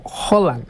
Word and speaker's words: Haaland: En realidad, dicen por ahí que doble A se pronuncia como Haaland.